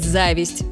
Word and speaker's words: зависть: Все это замечательно, но может вызвать зависть с ударением на первом слоге.